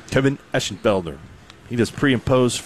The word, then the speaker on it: Eschenfelder: That's good pronunciation of his name, too, Mark. – Eschenfelder? – Yep.